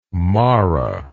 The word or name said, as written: mara